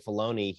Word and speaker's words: Filoni: Am I pronouncing his name correctly? – Dave Filoni, yeah.